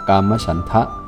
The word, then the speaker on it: กามฉันทะ: กามฉันทะแปลว่าความยินดีในกามความพอใจในกามใช้ว่ากามฉันก็ได้กามฉันทะ